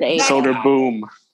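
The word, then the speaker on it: Söderblom: And even Soderblom, although he's in the Soderblom, I think. Blom, I don't know. – They pronounce it Bloom. Blom. – Soderblom. Yeah. – Okay. Soderboom, like Adam Cole.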